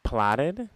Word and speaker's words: plaudit: Plotted? – Plotted.